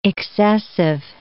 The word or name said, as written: excessive